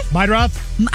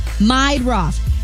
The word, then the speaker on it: Meidroth: Chase Myrdah? The White Sox guy? – Meidroth. – How do you say that? – Meidroth.